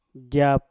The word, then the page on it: jap: Mpur